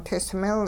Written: tismil

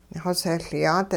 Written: xosehLyaat

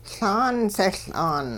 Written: Laan sehL'aan

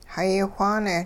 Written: hayi whaane

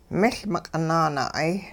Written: miL miq'i nanaa'ay

